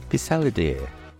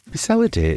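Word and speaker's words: Pissaladière: Pissalle d'Air. Pissalle d'Air is what some would call the French version of a pizza. It's spelt P-I-S-S-A-L-A-D-I-E-R-E.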